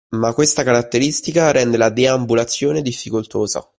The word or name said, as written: difficoltosa